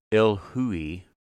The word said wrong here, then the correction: Illahe: It's Illahe winery would taste quite different, and it there were no surprises in me in the tasting.